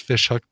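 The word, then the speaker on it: Ghoti Hook: How about a version by a band called Fish Hook, but that fish is spelled G-H-O-T-I. And you can look online to figure out why it's pronounced Fish Hook, but it's spelled Goatee Hook.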